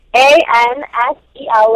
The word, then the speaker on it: Ansel: A N S E L, like Ansel, is his first name.